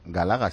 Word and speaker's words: Galaga: Se llama José Ramón Pellicer, que ha batido el récord del mundo de Galaga.